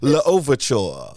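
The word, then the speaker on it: Louverture: So it's L'Ouverture. L'Ouverture. – That's the French.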